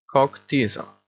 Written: cock-teaser

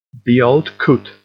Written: the old coot

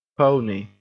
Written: pony

